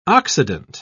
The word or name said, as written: occident